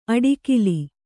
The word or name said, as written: ಅಡಿಕಿಲಿ